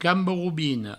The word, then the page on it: cambarobina: provençal; camboroubino